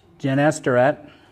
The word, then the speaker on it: Gennesaret: Gennesaret.